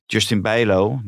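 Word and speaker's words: Justin Bijlow: Maar Justin Bijlo, nu zeg ik het goed, en Jasper Cillessen zijn 2 topkeepers.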